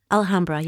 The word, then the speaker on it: Alhambra: Alhambra, yes.